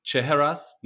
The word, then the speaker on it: Chehrehsaz: Today, I'm joined by Ali Chehrehsaz. No, I butchered that already. See, I told you. Give me the name one more time, Ali.